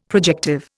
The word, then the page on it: projective: Indian